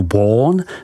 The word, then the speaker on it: Beaune: Côte de Beaune, vyslovko du Bon, se rozkládá od hranic obce Echevrone, vyslov Echevron, po Chagny, vyslovuj Šaní.